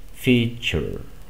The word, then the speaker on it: feature: Que esta terminación, ture, normalmente la vas a pronunciar como si estuvieras viendo la C y la H. Sure, future, feature, ¿ok? Futuro, future, feature, característica, ¿ok? Así es como suena.